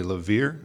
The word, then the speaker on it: Laveer: Laveer.